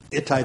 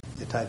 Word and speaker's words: Ittai: One of my favorites because his name rhymes with his home city, Ittai the Gittite.